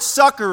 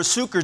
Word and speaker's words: succor: The word succor or succor, depending how you want to pronounce it, also means help.